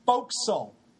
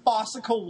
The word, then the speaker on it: fo'c'sle: Oh, and by the way, you motherfuckers, it's pronounced fo'c'sle. If I hear somebody say fossicle one more time, I'm fossicle.